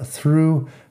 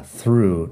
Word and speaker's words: through: Ahora que estamos, la pronunciación through piensa en TH, luego la R y luego la U larga, through. Nada más, tiene GH al final, eso es tema para el próximo capítulo, creo.